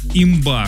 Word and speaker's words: имба: Имба, что такое? – Это, по-моему, читается как имба. – Имба, да, вот так вот правильно.